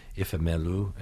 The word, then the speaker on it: Ifemelu: This novel follows Ifemelu and Obinze. Am I pronouncing it right? – Yes, I'm very impressed.